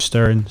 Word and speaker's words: Stearne: On any social media, it's at Drew Stern. Stern is spelled S-T-E-A-R-N-E, and if I don't spell it, no one ever finds it.